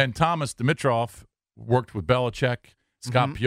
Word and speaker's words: Dimitroff: I've always thought his name was pronounced Dimitroff. – Okay. – And I was like throwing the I in there.